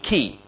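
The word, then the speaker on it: key (כי): Well, the Hebrew word there that is translated if here is the word, the Hebrew word key, and you can just spell it K-E-E, key.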